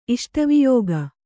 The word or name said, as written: ಇಷ್ಟವಿಯೋಗ